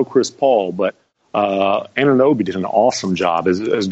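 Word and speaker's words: Anunoby: Help me on the pronunciation. – Ananobi. Yeah.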